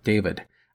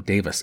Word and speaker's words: David: Note 1 I want to mention that I have a few times mispronounced the name of the expedition scientific director, Edgeworth David. I have called him Davis instead of David.